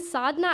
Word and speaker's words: Sadhana: It pushed me out of this fake identity I had created for myself, and it made me tell my teachers, my friends, the right way to pronounce my name. And when they started calling me Sadhana, I felt this burden lift off my shoulder.